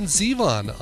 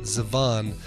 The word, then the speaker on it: Zevon: The music of Warren Zevon on today's show. I've been in the past, I've been guilty of pronouncing it Zevon. No, it's Zevon. Accent on the Z.